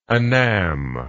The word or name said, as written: anam